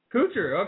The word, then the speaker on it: Kuchar: Matt Kuchar is that how you say it? Kuchar or Kuchar? – Kuchar. – Okay.